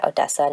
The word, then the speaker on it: Odessa: Um, just some background i i was born in ukraine in odessa it's it's so funny for me to like say odessa not odessa like the russian pronunciation that i'm used to to have to kind of say it in English because that's how you pronounce it here.